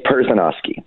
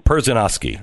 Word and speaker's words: Perzanowski: Yeah, tell me how you say his name. – It's Perzanowski. – Perzanowski.